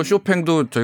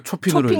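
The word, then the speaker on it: Chopin: 뭐 쇼팽도 저희가 초핑으로, 초핑으로 그렇죠.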